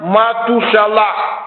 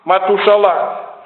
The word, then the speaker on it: Матушалах: А имя Сефита Мафусала, сына Еноха, Енох святой был, взят на небо, его надо произносить как Матушалах. Матушалах означает отсылающий, прогоняющий смерть.